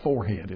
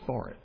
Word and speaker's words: forehead: It's not forehead, it's forehead.